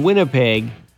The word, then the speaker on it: Winnipeg: Usually, the first syllable in Winnipeg is exactly what the Blue Bombers do at home win.